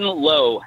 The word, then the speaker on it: Lowe: Is Brandon Rowe? Brandon Rowe? How's he say his name? – Brandon Lowe. – Lowe, I'm sorry, Lowe. Brandon Lowe.